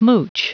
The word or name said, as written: mooch